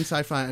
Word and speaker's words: Syfy: This aired on Syfy, which you might expect to be spelled like science, short for science fiction, but it's spelled S-Y-F-Y because, you know, fuck everything.